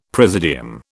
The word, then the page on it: presidium: British English